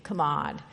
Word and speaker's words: chamad: And the Hebrew word for desire is kamad. It's spelled C-H-A-M-A-D, kamad.